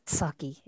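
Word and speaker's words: Psaki: There's a P, but it's pronounced just with the S. – Correct. Saki or something. – Yes. Saki.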